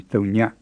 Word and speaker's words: thonya: The first is the ny sound in thonya. It's quite like the ny in the English word minion, but it's actually closer to the ny in words like seigneur in French or signore in Italian.